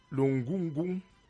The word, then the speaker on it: ngungung: L'ongungung, j'espère que je prononce bien. – Oui, l'ongungung.